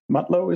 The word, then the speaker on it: Mutlow: Tonight, we're joined with Mr. Moose Mutlow.